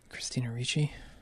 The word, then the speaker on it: Christina Ricci: Christina Ricci? – Yeah.